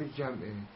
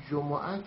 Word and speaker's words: جمعه: جمعه در این آیه اومده، اصل جمعه هست، ولی جمعه هم بگیم درسته، کما اینکه خمس اصلش فعله، خمس هم بگیم درسته.